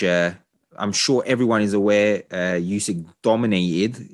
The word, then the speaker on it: Usyk: It was Oleksandr Usyk, I hope I pronounced that correctly, against Anthony Joshua. Is it Usyk? – Usyk.